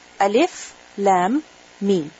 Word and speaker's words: الم: In the sense that you don't say ألم, you don't say ألم. How do you say it? Alif, Lam, Mim.